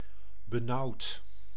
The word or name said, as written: gloomy